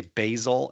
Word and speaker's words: Basil: I feel like with this name, you say Basil. I feel like if it's a spice, you say basil, and if it's a person, you say Basil.